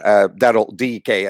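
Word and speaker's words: Daryl Dike: And then I think it was one of the last days in the transfer, they brought in Daryl DK, as it's pronounced. We all say, still say that, but Daryl DK.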